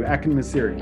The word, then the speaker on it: Akinmusiri: Yeah, pronounced like almost like Siri, Akinmusiri.